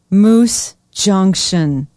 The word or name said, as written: Moose Junction